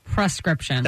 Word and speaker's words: prescription: Prescription. – That's what I would say, press. Yeah. – The correct pronunciation is prescription. Prescription. Not per.